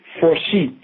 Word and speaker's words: Forshey: With the warden, his name is Jay Forshee, like Forshee's a jolly good fella. You pronounce it, you spell it actually, J-A-Y, and his last surname is Forshee, F-O-R-S-H-E-Y.